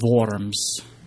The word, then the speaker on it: Worms: And this was taking place at the Diet of Worms. Oh, wait. It sounds better if you say it with the German pronunciation. The Diet of Worms.